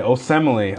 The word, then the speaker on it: Osemele: That's how you say it. Osemele.